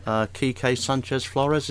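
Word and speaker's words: Kike Sánchez Flores: Yeah, Ranieri's nominated again Manager of the Month for December, along with Wenger, Kike Sanchez Flores, is that how you say it? – Yep.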